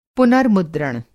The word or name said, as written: पुनर्मुद्रण